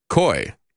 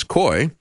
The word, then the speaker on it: Coie: They say it was Washington lawyer Mark Elias and his law firm, Perkins Coie, I think is the way you pronounce that, C-O-I-E.